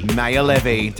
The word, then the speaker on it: Maor: Maor. Did I say it right? – You did.